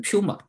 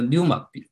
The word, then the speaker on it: pneuma: 如果就是这个发音发的，如果像我这种这个语言天赋不好的 pneuma，如果不小心发音成了，就会变成 puma，就是不是我们穿的那个运动服的那个 puma，这是应该这就 pneuma 可能比较对一点。那么这个词的话是跟希伯来文的那个 ruach 这个是对应的，它都是也可以翻译成呼吸、风和灵的意思。